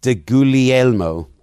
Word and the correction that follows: DeGuglielmo: I don't think you pronounce the G. I think it's Guglielmo. – Guglielmo.